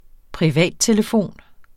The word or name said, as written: privattelefon